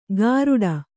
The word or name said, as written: ಗಾರುಡ